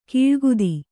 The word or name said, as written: ಕೂಳ್ಗುದಿ